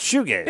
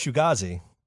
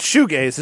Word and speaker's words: shoegaze: Yeah, it's shoegaze. – Yeah, shoegaze. – I thought it was shugazi. – No, it's shoegaze.